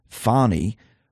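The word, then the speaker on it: Fani: Nathan was the special in more ways than one prosecutor engaged by Fannie, pronounced Fannie Willis,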